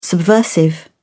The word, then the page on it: subversive: British English